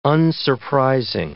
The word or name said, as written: unsurprising